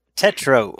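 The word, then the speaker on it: Tetreault: You may have to help me with this, but it looks like Greg Tetterault. – Tetro, I believe is how you say that.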